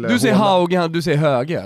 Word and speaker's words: Hauge: Ja, fast jag hörde Hauge själv säga Hauge. – Alltså, alla i Norge säger Höge. Han sa själv Hauge. Han tog en kolasinats med uttalet sitt eget namn fel för att eventuellt göra det mer internationellt.